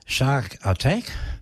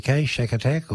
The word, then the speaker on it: Shakatak: Up next on Smooth Jazz, we listen to English jazz funk band Shark Attack. That's spelled S-H-A-K-A-T-A-K, Shark Attack or Shark Attack.